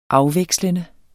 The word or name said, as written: AFVEKSLENDE